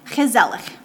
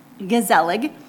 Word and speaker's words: gezellig: We really enjoyed that episode, especially one particular scene that talks about a Dutch emotion concept, gezellig. The Dutch guttural G makes that hard to pronounce for Americans, but it starts with a G, gezellig, gezellig.